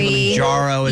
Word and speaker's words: Kilimanjaro: Kilimanjaro is the pronunciation of it.